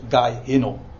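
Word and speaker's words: Gei Hinnom: Gehenna is gewoon, komt van het Hebreeuwse Gaihinom. Alleen het is de Griekse uitspraak, zeg maar.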